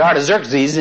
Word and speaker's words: Artaxerxes: Now, if you want to remember how to say Artaxerxes, say Xerox. Say it, Xerox. And you pronounce X, and as his name is Artaxerxes, you pronounce it like you pronounce Xerox.